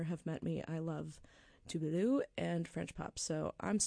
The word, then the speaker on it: Tove Lo: The correct pronunciation, I know I sound pretentious, but I'm just trying to be respectful of how it's pronounced. She's Swedish, so it's Tove Lo.